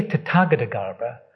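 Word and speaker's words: Tathagatagarbha: Now I say Tathagata Garbha and not Tathagata Garbha, not because I'm Irish, because that is as far as an English person goes, or a Scottish person, a British person, that's how you pronounce it.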